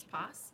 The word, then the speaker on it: Poss: To be fair, it rhymes with my last name, which is Poss.